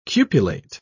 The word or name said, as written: cupulate